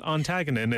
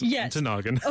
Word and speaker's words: Ontonagon: It's not Ontagonon. It's Ontonagon.